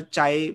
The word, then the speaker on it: chi: Chi or chai, depending on how you pronounce it.